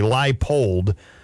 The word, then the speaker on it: Leipold: Lance Leipold, I know John said it wrong, but I'm forgiving him because a lot of people, but it's actually Leipold.